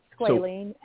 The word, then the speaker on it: squalene: That squalene, is it squalene or squalene? How do we even pronounce it? Squalene? – It's squalene.